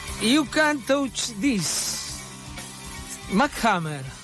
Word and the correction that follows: MC Hammer: MC Hammer.